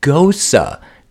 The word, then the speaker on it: gose: Better bet your bottom beer steins, my gals, the beer bracket girls are enjoying a nice gose. That's how you pronounce it, though it's spelled like rose.